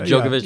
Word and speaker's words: Djokovic: And he was like before Djokovic. Am I saying that right? – Yeah, right. Djokovic.